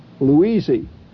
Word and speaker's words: Loweezy: That's the hillbilly way of pronouncing Louise, Loweezy.